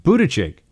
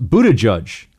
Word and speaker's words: Buttigieg: It should be pronounced Buttigieg. Buttigieg. Okay.